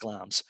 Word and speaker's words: Glamis: It's spelled Glamis if people are looking for it, but it's pronounced Glamis.